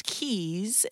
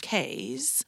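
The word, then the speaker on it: cay: Now, if you see that word written, it looks like a K, C-A-Y. But when you go to the Bahamas and you hear about or you see the little islands, you'll know that they're pronounced keys instead of K's, like a key like you're putting into a door, and that will make you look like you have been there before.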